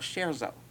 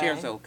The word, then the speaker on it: Sharezo: And also, the name of that comedy channel is called Sharezo. And it's spelled S-H-A-R-E and then Z-O. – Okay. – Sharezo Comedy.